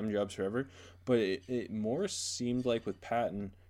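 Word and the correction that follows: Paton: that I'm pronouncing his last name wrong. It's Peyton. It's pronounced Peyton, AJ.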